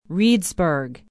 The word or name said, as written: reedsburg